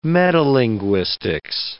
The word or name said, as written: metalinguistics